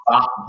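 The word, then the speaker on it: ba (八): Fa, ba, ba is how you say 8 in China.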